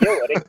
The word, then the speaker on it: Göring: Det uttalas Göring.